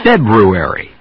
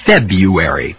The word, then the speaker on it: February: February. Or February, both pronunciations are okay.